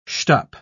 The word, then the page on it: shtup: British English